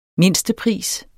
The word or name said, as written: mindstepris